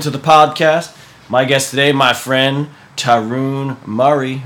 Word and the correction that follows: Marya: Tarun Marya.